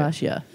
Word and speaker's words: Bhatia: Bashia. B-H-A-T-I-A. – Yeah. Bashia. Yeah, Bashia. Yeah, there's not too many other ways they could go.